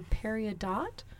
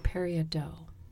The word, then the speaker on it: peridot: People pronounce it differently depending on where you live, but it's called a peridot or a peridot. Probably in French it's a peridot. – Yeah, it has like that dough thing that French usually has. – Yeah, D-O-T. So in English we would say dot, right? – Yeah.